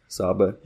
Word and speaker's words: Saba: Saba, yeah.